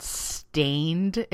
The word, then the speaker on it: Staind: It's like um, uh, it's from 2001, and it was by a band called Stained, except it's spelled S T A I N D. So there's no E, just Stained without the E.